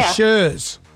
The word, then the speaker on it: Chers: Shers or Shers? – Shers. – Because girls here have written your name as C-H-E-R-S. Is that how you spell it? – That's it.